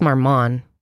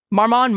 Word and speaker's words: Marmont: It's just Marmont. The T is silent, right? Chateau Marmont? – Sure. Marmont, Marmont.